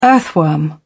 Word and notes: earthworm: British English

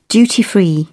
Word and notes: duty free: British English

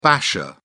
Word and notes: basher: British English

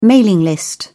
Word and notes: mailing list: British English

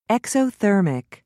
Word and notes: exothermic: British English

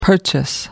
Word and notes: purchase: American English

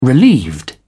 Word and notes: relieved: British English

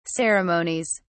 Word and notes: ceremonies: British English

